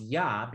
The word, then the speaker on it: Jah: So for instance, a lot of people use hallelujah but they recognize it's spelled J-A-H, Jah. That's a whole Gutenberg press German language lesson. I'm not going to do it today, but it's pronounced Yah because there's no J sound in Hebrew, but the Jah part is a part of what many JW say is the name of God.